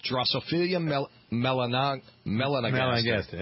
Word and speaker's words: Drosophila melanogaster: Drosophila melanogaster.